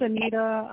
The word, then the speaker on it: Anita: So, Mr. Rice, my name's Anita.